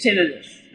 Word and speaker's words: tinnitus: Now, all right, so since you experience it and you're a doctor, what is the correct pronunciation? Is it tinnitus or is it tinnitus? I've heard it both ways. – It's tinnitus. – Oh, man. – I always say tinnitus, but it is tinnitus.